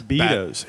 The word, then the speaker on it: Beto's: How is it pronounced? Because I thought it was... It is Beto's? Bados. – Beto's. – He said I feel like Beto's. He said Beto's.